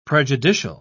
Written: prejudicial

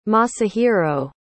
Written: masahiro

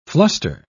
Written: fluster

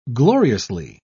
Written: gloriously

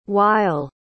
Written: weil

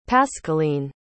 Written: pascaline

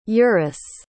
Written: eurus

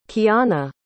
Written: kiahna

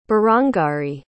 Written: berangari

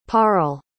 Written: parle

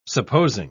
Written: supposing

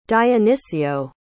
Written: dionicio

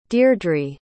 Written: deirdre

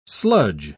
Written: sludge

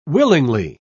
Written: willingly

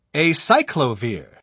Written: acyclovir

